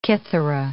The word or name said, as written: kithara